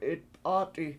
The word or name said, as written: 7itp7AAt7i